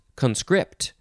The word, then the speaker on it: conscript: Third form of conscript is actually pronounced conscript. Second syllable is emphasized.